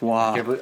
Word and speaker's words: Roy: It's pronounced Wah.